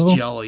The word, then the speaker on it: giallo: They referred to it as a giallo. – It's giallo, yeah.